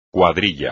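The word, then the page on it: cuadrilla: español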